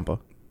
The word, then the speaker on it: Ciampa: Tommaso Ciampa. I don't even know how to say his name anymore. – Ciampa.